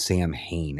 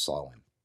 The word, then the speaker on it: Samhain: It's commonly mispronounced as Samhain, and it's only because of the Gaelic spelling, but it's actually pronounced Samhain.